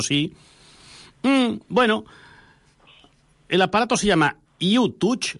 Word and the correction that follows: Lutouch: ¿Lo he dicho bien, Luis? – Lutouch. – Ah, Lutouch. Lutouch. Vale.